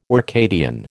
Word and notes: Orcadian: American English

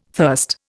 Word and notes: thirst: Indian

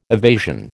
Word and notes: evasion: American English

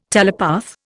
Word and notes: telepath: Indian

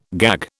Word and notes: gag: British English

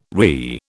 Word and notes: RE: British English

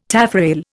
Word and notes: taffrail: Indian

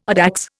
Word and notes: addax: Indian

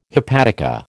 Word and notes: hepatica: American English

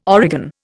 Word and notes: origan: Indian

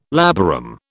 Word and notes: labarum: British English

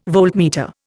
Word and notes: voltmeter: Indian

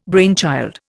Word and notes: brainchild: Indian

